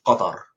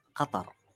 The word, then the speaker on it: Qatar: Pretty much every consonant in the word is not found in English. Qatar. – So just to recap, the official pronunciation of my country is Qatar. Qatar. – Qatar. – So go ahead and close your throat. – It's very guttural.